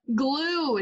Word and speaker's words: Gluch: Glue. It's German, like Elmer's glue. – Glue. Oh, silent C-H, huh? – Yeah, C-H is silent.